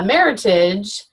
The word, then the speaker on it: Meritage: And so I picked out a Meritage, and I said, you know, when you order it, make sure you say Meritage, rhymes with heritage,